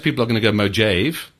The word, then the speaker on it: Mojave: And I've got to look at how I wrote it phonetically because now it still makes no sense. Mojave. – I think that's right.